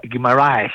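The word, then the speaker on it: Guimarães: Έτσι το λένε αυτό το Βραζιλιάνο στα χαφ, τον Μπρούνο Γκιμαράις. – Μπρούνο Γκιμαράις. – Ναι, το λένε Γκιμαράις.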